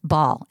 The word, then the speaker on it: Baal: Baal, excuse me, it's Baal. I remembered that I've pronounced that wrong my whole life.